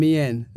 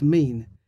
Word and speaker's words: mien: I've been saying mien. It's a French word. It's actually an English word. – Oh, yeah. – Pronounced mean, which means the appearance or manner.